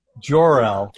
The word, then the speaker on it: Jor-El: Jor-El. Yeah. Hard J.